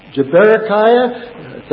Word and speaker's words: Berechiah: Berechiah, that's how you say it, Yahweh blesses.